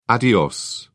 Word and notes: adios: British English

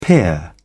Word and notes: peer: British English

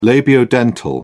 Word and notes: labiodental: British English

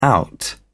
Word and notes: out: British English

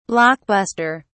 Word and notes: block buster: British English

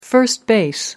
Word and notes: first base: American English

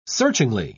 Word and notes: searchingly: British English

